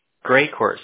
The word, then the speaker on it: Greycourt: 38 Raycode Avenue? – Sorry if I'm saying that wrong. – Great Court.